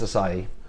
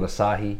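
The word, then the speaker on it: açaí: Uh, and I love it because there's a lot of people still can't pronounce acai. They call it asahi.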